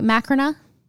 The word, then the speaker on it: Macrina: Am I saying her name right, Macrina? – Typically, we say Macrina.